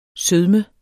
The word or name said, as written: sødme